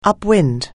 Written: upwind

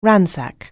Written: ransack

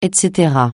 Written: et cetera

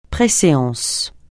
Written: préséance

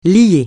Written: lier

